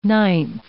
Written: ninth

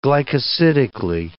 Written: glycosidically

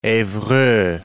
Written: evreux

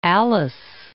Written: Alice

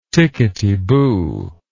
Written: ticketyboo

